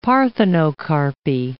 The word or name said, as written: parthenocarpy